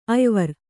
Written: ಅಯ್ವರ್

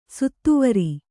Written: ಸತ್ವರ

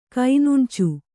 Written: ಕೈನುಣ್ಚು

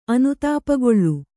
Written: ಅನುತಾಪಗೊಳ್ಳು

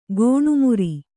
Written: ಗೋಣುಮುರಿ

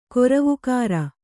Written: ಕೊರವುಕಾರ

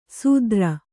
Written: ಸೂದ್ರ

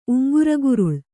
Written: ಉಂಗುರಗುರುಳ

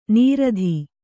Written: ನೀರಧಿ

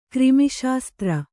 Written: ಕರ್ಮಶಾಸ್ತ್ರ

